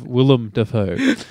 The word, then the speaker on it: Willem: William Dafoe. – Yeah. I have Willem Dafoe.